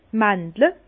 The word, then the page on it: d' Màndel: Bas-Rhin; Herrlisheim